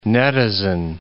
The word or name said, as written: netizen